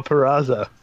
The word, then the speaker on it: Purrazzo: He said Pizarro. – Deanna Pizarro. Deanna Purrazzo. Yep.